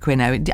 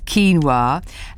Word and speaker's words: quinoa: As we used to say when we first saw, well, actually, producer Bex used to call it quinoa. I think she still does, maybe. But anyway, it's quinoa.